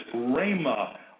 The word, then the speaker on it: rhema: And that Greek word is a word we would pronounce like this, rhema. R-H-E-M-A, rhema.